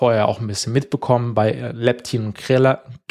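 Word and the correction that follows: Ghrelin: oh Gott, ich kann es kaum aussprechen. Krelin.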